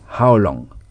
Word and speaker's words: How long: sans prononcer le G. How long? – How long?